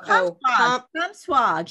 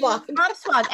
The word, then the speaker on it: Comsewogue: Oh, Comswog. She's great. Comswog.